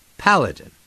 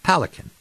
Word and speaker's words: Paladin: He mispronounced Paladin? I wrote it down and corrected. Paladin. Like his name, sort of. Palakin.